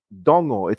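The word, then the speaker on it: dogo: Dongo. It's D-O-G-O, but pretend there's an N in the middle.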